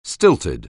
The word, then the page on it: stilted: anglais